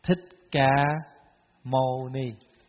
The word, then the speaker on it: Thích Ca Mâu Ni: Sakyamuni, we pronounce into Vietnamese. Thích Ca Mâu Ni.